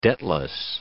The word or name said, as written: debtless